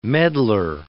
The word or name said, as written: medlar